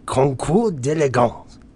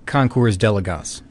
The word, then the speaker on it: Concours d'Elegance: The Concours d'Elegance. – Well, nice work. I just say Concours d'Elegance.